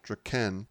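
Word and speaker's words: Drakkon: But well, because when I wrote it down, I actually wrote out D-R-A, even though it's spelled D-R-A-K-K-O-N, I put it like D-R-A-H, like to emphasize the, like, that was a tasty beverage. – Oh, God. – And then I put a D-R-A-H hyphen K-E-N because it's pronounced Draken or Draken.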